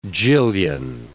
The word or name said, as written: jillion